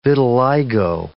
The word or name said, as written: vitiligo